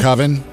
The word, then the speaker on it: coven: Is it coven? – A coven. – With all the witches. Every time I say it wrong.